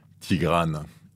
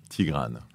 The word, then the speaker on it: Tigrane: How do you say it in English, Tigrane? – I guess they pronounce most of the time Tigrane. – Tigrane. – Yeah. – And you prefer Tigrane? If you meet someone in English, you would say, my name is? – Tigrane, if you pronounce it the French way or the Armenian way, since it's a name from Armenia.